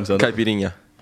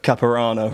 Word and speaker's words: caipirinha: maybe you can help me with the pronunciation. – Caipirinha. – Caipirinha. – What were you calling it? – Caparana or something.